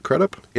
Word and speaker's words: Crudup: His son, Will Bloom, played by Billy Crudup. Is that how you say it? Crudup? – It is. It's Crudup. Yeah. – Yeah. – And, you think it's Crudup, but it's actually Crudup.